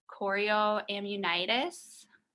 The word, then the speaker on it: chorioamnionitis: And at that time I learned that I had an infection in my amniotic fluid called chorioamnionitis. Am I saying that correctly? – That sounds good.